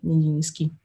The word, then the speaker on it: Nijinsky: di Nijinsky, non so bene come si, si, si legga, comunque Vaslav Ninsky, Nijinsky. si scrive N-I-J-I-N-S-K-Y ed era appunto un ballerino eh,